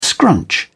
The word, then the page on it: scrunch: British English